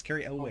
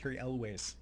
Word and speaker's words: Elwes: It's Cary Elwes. – Oh, he's great. – Is that how you say it? – Cary Elwes. Yeah.